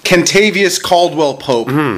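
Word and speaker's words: Kentavious Caldwell-Pope: So just so everyone's clear, it's... Kentavious Caldwell Pope is the correct pronunciation.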